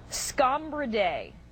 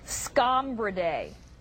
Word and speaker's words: Scombridae: Well, let me make sure I'm saying it right. Scombridae? – Scombridae. – Scombridae. – Yes.